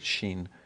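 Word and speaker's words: Shin (ש): We would pronounce it Shin.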